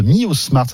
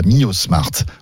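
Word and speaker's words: MiYOSMART: Il s'agit de Miosmart, ça s'écrit MIYOSSMART. Voilà, Miosmart.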